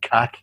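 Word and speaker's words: cack: But then I was going, hold on, but I always thought the word cac, which is pronounced cac, mm-hmm. in England, but pronounced kick here, comes from the word caca, which is the Irish for shite.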